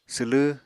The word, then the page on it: swlw: apatani